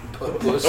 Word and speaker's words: Busch: Busch. – It's the original German, so it's Busch.